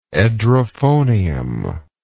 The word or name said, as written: edrophonium